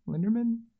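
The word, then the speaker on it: Lindemann: And then Maggie, I'm terrible at pronouncing her last name, Maggie Linderman. Linderman. – That's how I pronounce it.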